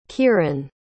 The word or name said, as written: kyran